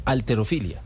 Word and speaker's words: halterofilia: ¿Cómo se dice? Alterofilia.